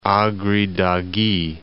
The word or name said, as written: Agri Dagi